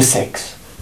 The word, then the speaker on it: Saix: Elle me dit, le sexe. Bon, est-ce que c'est une blague? Elle me dit, non, non, c'est le sexe. Bon. Et ça s'écrit S-A-I-X.